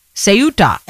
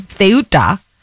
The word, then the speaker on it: Ceuta: It could be your word of the day, Ceuta. And it could either be an S sound at the beginning or a TH sound at the beginning, Ceuta, depending on the dialect that you are speaking it in.